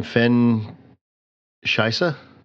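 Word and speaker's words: Fenn Shysa: What's the pronunciation of the name? Fen Shisa? – I believe so, yeah.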